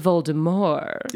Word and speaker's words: Voldemort: And then we have, it's pronounced Voldemort.